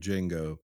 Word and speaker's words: Django: He's like, Django. The D is silent.